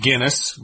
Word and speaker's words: Guinness: The word Guinness is pronounced Guinness, like the beer from Ireland, which is actually a stout beer, but that's another subject.